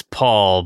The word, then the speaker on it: Pole: It's Paul, but it's spelled P-O-L-E, but the E is silent.